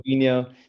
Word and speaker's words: Binho: Binyo. It's like the Portuguese pronunciation. Inyo. Inyo in Portuguese means like little.